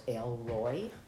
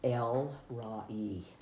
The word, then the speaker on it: El Roi: I always thought it was Elroy because it's spelled E-L-R-O-I, but it's El-Ra-E, and the God who sees us.